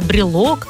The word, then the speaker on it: брелок: Но слово «брелок» особенное. Оно пришло к нам из французского языка и старается сохранить это важное ударное «о». Всегда на «о» будет ударение.